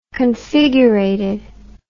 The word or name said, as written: configurated